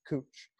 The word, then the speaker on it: Couch: I lived on 18th and Cooch, which is spelled the same as couch, but in Portland is pronounced Cooch.